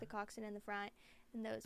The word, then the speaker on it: coxswain: It's spelled like C-O-X-S-W-A-I-N. – Coxswain? – But that's how you say it.